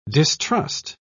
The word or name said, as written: distrust